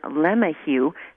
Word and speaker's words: LeMahieu: He pronounces it LeMahieu. However, his own cousin says LeMahieu. So they have a little dispute amongst each other.